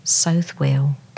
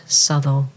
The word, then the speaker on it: Southwell: Note the pronunciation is not, as many readers assume, Southwell, but Southwell.